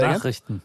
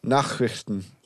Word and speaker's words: Nachrichten: The Nachrichten. – Are you ready? Is that how you say it? Say Nachrichten. – Again? Nachrichten. – That's German.